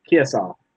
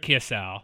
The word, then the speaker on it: Kiesau: Eric, is it Kiesu? Kiesu? – Kiesau. – Kiesau. Okay, that makes more sense. Eric Kiesau. – Or Kiesau. Kiesau. Like, think of like Esau, the name. I think it's Kiesau.